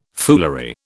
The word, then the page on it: foolery: British English